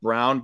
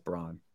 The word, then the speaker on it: Braun: I guess they pronounce it Brown, but I'm going to still pronounce it Braun.